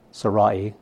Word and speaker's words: Sarai: Sarai is how it's actually pronounced. It sounds a little strange,